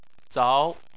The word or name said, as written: zao2